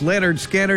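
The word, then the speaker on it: Lynyrd Skynyrd: KZ106 from pronounced Leonard Skinner at number two on this year's Memorial Day 500.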